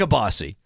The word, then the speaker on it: kielbasa: Is it Kielbasa or Kielbasi? – It's Kielbasi. – Kielbasi. – Even though it's spelled Kielbasa.